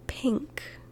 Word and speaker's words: pink: But we also spell it a different way, like in pink. Pink. You might think, oh no, that's just P I N K. There's no G sound in there. No, but there is an NG sound in there. Sorry. Ping. Like ping pong. Do you pronounce it any differently? Nope. It's just with a K on the end. Pink. So